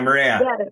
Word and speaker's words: Moorea: Oh, yeah, Moorea.